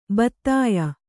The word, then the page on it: ಭೇದ್ಯ: bhēdya